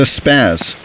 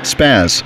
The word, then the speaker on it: Spazz: Dave the Spaz. – Dave? – The Spaz. – Dave the... – Spaz. – Stan? – Spaz. – Spell it. – S-P-A-Z-Z.